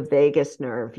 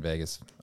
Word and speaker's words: vagus: It's called the vagus nerve? – The vagus nerve, yeah. – Spelled the same way? – Yep. V-A-G-U-S. – Okay. I was thinking Vegas.